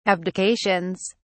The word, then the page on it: abdications: British English